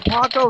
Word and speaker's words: Paco: you know, Paco rhymes with taco.